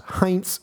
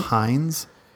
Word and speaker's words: Heinz: Isn't it Heinz? – If you say so. – Heinz? – Heinz! – What the fuck is Heinz? – It's the German pronunciation of a German word.